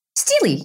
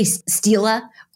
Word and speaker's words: stele: Is it Stele? – Stele, yes. – Stella? – Basically, just say Stela or... And that'll work for the singular and the plural. Stella, Steely, okay. Really either way is fine.